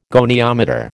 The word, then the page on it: goniometer: American English